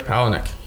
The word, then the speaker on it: Palahniuk: Chuck Palahniuk.